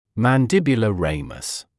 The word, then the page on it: mandibular ramus: [ˌmæn’dɪbjulə ‘reɪməs]; [ˌмэн’дибйулэ ‘рэймэс]